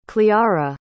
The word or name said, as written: cleora